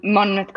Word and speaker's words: Monmouth: But I'd love to backtrack a little bit just to where you made that leap to work for, was it Mermouth? How do you say that? – Monmouth Coffee. – The Monmouth. – Yes.